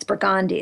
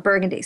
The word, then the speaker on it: Burgundy: It's Burgundy, it's not Burgundy.